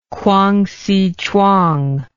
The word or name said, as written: Kwangsi Chuang